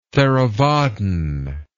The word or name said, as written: theravadin